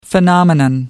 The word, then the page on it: phenomenon: American English